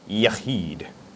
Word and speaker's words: Yachid: And I actually looked up Hebrew pronunciation, and so I wouldn't embarrass myself up here mispronouncing these words, but that's actually how you pronounce it. And that is spelled Y-A-C-H-I-D, Yahid.